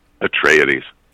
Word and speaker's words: Atreides: Atreides. Paul Atreides. He is descended from the Greek house of Atreus, which was King Agamemnon, going back to Greek mythology.